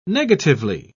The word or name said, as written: negatively